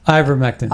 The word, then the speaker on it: ivermectin: Ivermectin.